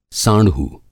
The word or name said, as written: साँढ़ू